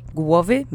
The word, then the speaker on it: głowy: Głowy means head. Let's say głowy three times. Accent is on the first syllable.